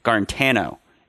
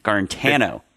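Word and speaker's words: Guarantano: Tennessee was up 13-3 heading into halftime, but Jarrett Garnt. I'm sorry, we have a new pronunciation on Jarrett's name. Let me. Garantano. It's a different pronunciation on the second A there, or the third A. Garantano.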